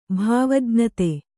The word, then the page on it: ಭಾವಜ್ಞತೆ: kannada